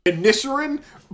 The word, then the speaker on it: Inisherin: Inisherin. – I have not been pronouncing that first N. I've been going Isherin. I just ignored that one.